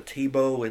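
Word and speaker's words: TIBO: I think it's pronounced the Tibow. It's T-I-B-O.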